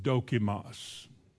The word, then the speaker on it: dokimos: The word tried is a Greek word pronounced dokimos. Dokimos.